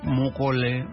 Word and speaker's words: Moukole: Moukole, Moukole, comme ça, t'aimes qu'on prononce ça. – Roger Tchondo Moukole Muntu.